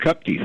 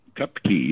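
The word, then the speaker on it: Cupka: It's C-U-P-K-A, that is? – But it's pronounced Cupty, thank you. – Cupty. – Cupkey, yes. – Interesting. – Like it had an E on the end, but it's got an A.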